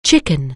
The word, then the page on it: chicken: English